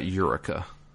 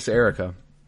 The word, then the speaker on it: Erica: They call her Erica. – Yeah. – It's not Eureka. – Yeah, but in my mind, it's Erica now. It started off as like Eureka, basically, yeah. Even though it's not how it's spelled.